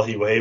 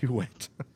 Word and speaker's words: Huet: I'm old enough to remember Cristobal Huey when it's known as Cristobal Huet. – Huet. – It was. He changed the pronunciation of the name.